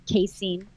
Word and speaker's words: casein: And then in January, we took out dairy, which is the casein. Casein, is that how you say it? – Yeah.